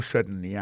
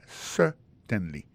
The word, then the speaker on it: certainly: Alors, en parlant de accent, gardez bien pour un mot certainly, accent tombé sur le premier syllabe là. Yes, certainly.